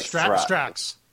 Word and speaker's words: Strax: It rhymes with fax. It's like Strax.